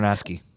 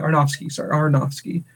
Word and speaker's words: Aronofsky: And then I don't know if you watched the scenes, like they added some bonus content on the DVD with Aronofsky, right? Or how are you pronouncing it? Darren Aronofsky. – Yeah, Aronofsky.